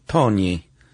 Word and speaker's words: Tognis: Do you know how to pronounce this one? T-O-G-N-I-S? – I would say Tonis. – Tonis, that's what I was going to say.